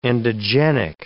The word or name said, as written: endogenic